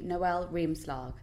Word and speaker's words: Noelle Riemslag: In today's episode, we've got a lovely guest with us, a lifestyle blogger who's trying to live more consciously, Noelle Riemslag.